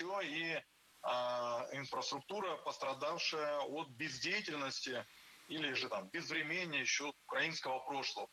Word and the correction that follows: безвременье: Безвременье, неверно, правильно, безвременье. Ударение в этом слове падает на корень врем. Время, безвременье. Следующий пример попал в наш выпуск все из того же утреннего шоу.